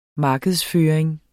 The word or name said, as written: markedsføring